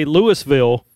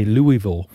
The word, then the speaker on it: Louisville: Just like we say Louisville in Mississippi, but somebody in Kentucky might say Louisville.